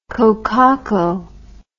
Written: kokako

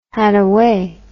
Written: hadaway

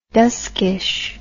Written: duskish

